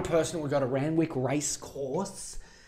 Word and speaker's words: Randwick: And you're the kind of person who would go to the Randwick, yeah, that's how you say it.